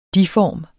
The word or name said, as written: De-form